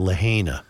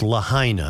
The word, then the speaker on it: Lahaina: I've been pronouncing it Lahaina. It's Lahaina. I've gotten many emails to that end, and I will now pronounce it correctly.